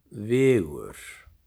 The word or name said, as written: Vigur